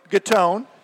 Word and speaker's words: Gattone: It's Joe Gattone. Say it right.